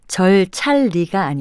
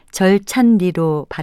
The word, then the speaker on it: 절찬리: 제가 방금 말씀드린 것처럼 절찬리의 발음은 절찰리가 아니라 절찬리라는 점에 유의하셔야겠습니다. 표준 발음법을 보면 니음 받침으로 끝나고 독립성이 있는 이음절 한자어에 리을로 시작된 이름절 전미사가 결합될 때는 리을이 니은으로 발음된다는 규칙이 있기 때문입니다. 절찬리는 니은 받침으로 끝나는 명사 절찬과 리을로 시작하는 전미사 리가 결합된 것으로 절찬리로 발음하고요.